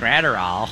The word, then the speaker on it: Graterol: Gratterall. – Let's just like practice. – Declan, play the video real quick. – You've heard my Minnesota pronunciation of it. – I do the same thing. – It's Gratterall.